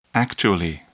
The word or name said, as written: actually